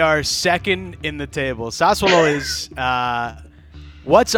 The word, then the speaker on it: Sassuolo: Sassuolo. Is that how you pronounce it? Sassuolo? – Yeah, Sassuolo, yeah.